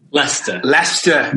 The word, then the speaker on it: Leicester: Is it pronounced Leicester? – Leicester. – No, it's one of our stupid English spellings. – It's very confusing, I will say. – It's spelled Leicester, but it's pronounced Leicester. Yeah.